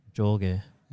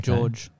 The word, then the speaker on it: Jorge: If he's Portuguese, it'd be a hard J. – Jorge. Yeah. – Okay. George.